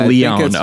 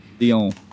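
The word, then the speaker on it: Léon: I think it's the French name. It's Leon. Okay. – Leon.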